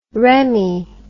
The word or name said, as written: remi